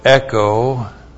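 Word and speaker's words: echo (ἔχω): And then we have echo. E-C-H long O, echo.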